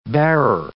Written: bearer

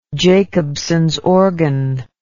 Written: Jacobson's organ

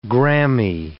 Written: Grammy

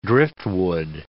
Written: driftwood

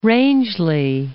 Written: Rangeley Lakes

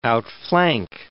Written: outflank

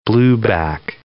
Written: blueback herring

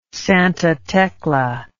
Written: Santa Tecla

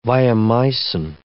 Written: viomycin

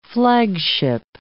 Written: flagship

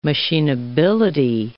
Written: machineability

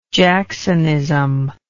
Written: jacksonism

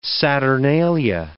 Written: saturnalia

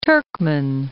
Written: Turkmen

